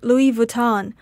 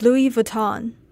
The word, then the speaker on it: Louis Vuitton: This is embarrassing, but I used to pronounce Louis Vuitton like Louis Vuitton. But in fact, the correct pronunciation is Louis Vuitton.